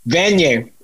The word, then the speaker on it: Vanier: The Jean Vanier Catholic? – Vanier. And this is one of the things that always happens. Like, Canadians see French words and pronounce them in French.